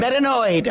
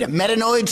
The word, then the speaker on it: Metanoid: Metanoid. Metanoid Smanghanger, the Homunculus. – Holy cow. Really was off. – Hello, Metanoid.